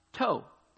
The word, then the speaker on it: tau: The Hebrew word used for mark in verse 4 is the last letter of the Hebrew alphabet pronounced tau, spelled T-A-U or T-A-W.